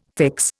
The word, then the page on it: fix: Indian